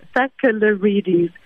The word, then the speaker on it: Sakellarides: a good a, a, good clue is just as a syllable by syllable. So Sakellarides.